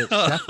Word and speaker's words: Schefter: No N in it. What are you talking about? – Scheffner? – Dude, I think Schefter? We all say Schefter around here.